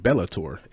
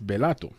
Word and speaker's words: Belato: Not to be confused with Bellator, it's Belato. Ends with an O.